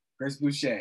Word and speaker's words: Chris Boucher: But another guy that I've been thinking about is Chris Boucher. Is that how you say his? – Chris Boucher.